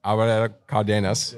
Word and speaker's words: Álvaro Cárdenas: Álvaro Cárdenas.